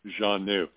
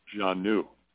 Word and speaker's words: Xanu: That's their name, Xanu. An emphasis on the second syllable, Xanu.